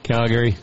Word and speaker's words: Calgary: How you say it? – Calgary. He's got too much of a drawl on the Gary part.